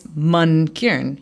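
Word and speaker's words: Mankiran: But I've always been stubborn where I'm like, no, it's Man Kiran.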